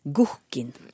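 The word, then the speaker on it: guhkkin: Mm, og det samiske ordet for langt borte, Sylfest, det uttales gohkin.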